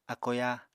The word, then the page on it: ákoyá: apatani